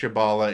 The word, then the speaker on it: Kabbalah: Okay, with the birth of Kabbalah, in Jewish Kabbalahs, I can't pronounce that. Kabbalahism? – Kabbalah. – Did I say that right? – I believe that's how you say it.